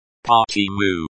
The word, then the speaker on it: Почему: The stress in. – Почему? – Falls on the second syllable. Почему? – Почему?